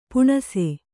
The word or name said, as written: ಪೀನಾಸಿ